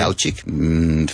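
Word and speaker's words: Daučík: Dauchik, because they have some accent in the C, or some hats in the C is Che, and it's okay. Dauchik.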